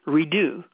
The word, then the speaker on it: Rideau: Her name is Bridget Radeau, R-I-D-E-A-U. And sometimes in French it's pronounced Redeau.